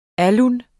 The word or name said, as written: alun